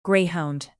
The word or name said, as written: greyhound